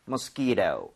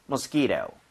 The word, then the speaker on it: mosquito: 뒤에 t라고 하는 부분은 모음 사이에 t가 있으면. 플랩이라고 해서 리을 비슷하게 발음이 됩니다. 그래서 네. – mosquito 해도 틀린 거 아니에요. 그런데도 부드럽게 mosquito 이렇게 하는 거 추천을 드리겠습니다. 두 번 여러분 해보도록 할게요.